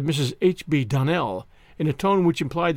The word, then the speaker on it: Donnell: "Clarice Almira told me that you pronounced our name Donnell." "Now, Miss Shirley, the correct pronunciation of our name is Donnell. Accent on the last syllable.